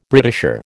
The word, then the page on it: Britisher: American English